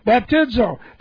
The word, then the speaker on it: baptizo: Okay, B-A-P-T-I-Z, long O, baptizo.